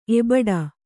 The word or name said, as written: ಎಬಡಿ